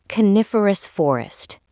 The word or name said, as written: coniferous forest